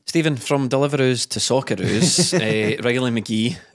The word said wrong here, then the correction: McGree: You had a go at me for getting his name wrong and you just done it there. – I said Riley McGree. – Yeah, he said McGee.